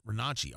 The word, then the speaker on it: Renacci: I think you pronounce it Renacci, R-E-N-A-C-C-I. I think that's how you do it.